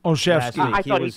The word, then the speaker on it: Olszewski: Olszewski.